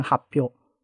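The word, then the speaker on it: 発表: 然后可以用汉字动词写成的写法叫做发表，日文念成 happy 啊，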